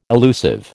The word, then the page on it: illusive: American English